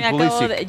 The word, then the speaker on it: Pulisic: Pues porque Pulisic, él ha pedido en entrevistas, en el anterior mundial, recuerdo, nos tocó cubrir Estados Unidos, y él, como tiene origen polaco, es la correcta pronunciación.